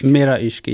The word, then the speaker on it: Mera Ishq: How do you pronounce it? Mera Ishq? – Mera Ishq. Yeah.